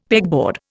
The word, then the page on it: pegboard: Indian